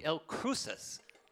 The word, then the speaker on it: El Cruce: El Cruces. Is that how you say it? – Sort of. – Sort of? – Yeah. – Come on, help me with the Spanish here. – El Cruce.